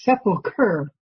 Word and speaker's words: sepulcher: If you're interested, that word sepulcher is sepulcher. It's pronounced sepulcher.